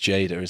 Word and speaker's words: Jada: I can never know how to pronounce her name. – I think it's Jada, isn't it? – Jada, yeah. The source also revealed he has always maintained that Will gave him permission to sleep with Jada, something they have publicly denied.